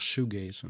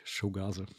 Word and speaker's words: Shoegaze: Das heißt ja auch Shoegaze und nicht Showgaze.